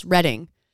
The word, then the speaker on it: Reading: I was in Redding. Also pronounced Redding. – Oh, like in California, Redding? – Yes, but it's spelt Reading.